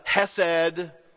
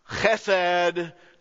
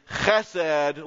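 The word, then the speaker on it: chesed (חסד): It is the hesed, love of God. If you say it correctly, it is the chesed, love of God. Y'all wanna do that? Let's do the Hebrew way. The chesed, love of God.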